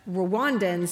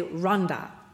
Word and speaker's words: Rwanda: That's how Americans say it. But Rwandans say Rwanda. They do not say Rwanda.